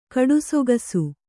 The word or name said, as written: ಕಡುಸೊಗಸು